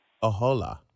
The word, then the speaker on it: Ahola: Ahola. – A hola, yeah, hola, like a schwa at the end.